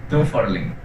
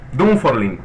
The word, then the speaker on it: Dunfaurlin: Bueno, la primera pregunta, a ver si lo dije bien, ¿es Dunforlín o Dunforlín? – Dunforlín. – Dunforlín, perfecto. Bueno, vale la corrección.